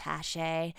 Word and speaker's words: Hachette: Oh, it's just pronounced Hachette.